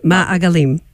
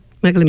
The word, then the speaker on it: Ma'agalim: I played, see if I'm going to say it right, Regalim? Magalim. Ma'agalim. – Magalim. Yeah.